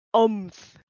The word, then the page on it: Omnath: Phyrexian